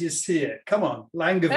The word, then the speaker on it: Langevin: And am I saying that right, Neil? Langevin? – I think you're saying it how I say it. I guess it's probably now you've brought it up. Presumably French, Langevin.